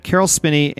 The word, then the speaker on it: Carol Spinney: That means that Carol Spinney and Diane Carroll will join Robert Forster to round out our 16th apartment. – I would like to say that you did pronounce that correctly, because I, in fact, know a person who made a customized cuckoo clock for Carol Spinney when he retired from Sesame Street.